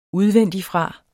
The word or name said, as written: udvendigfra